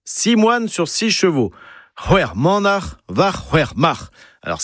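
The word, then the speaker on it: c'hwec'h: Hwer, c'est six, c'est apostrophe H au début et à la fin.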